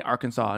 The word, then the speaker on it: Arkansas: Arkansas. Yes. So they don't pronounce the S.